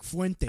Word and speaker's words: Fuentes: You got the Gripers there, Nick Fuentes. I can't pronounce his last name. I'm a little retarded about that right now. – Nick Fuentes.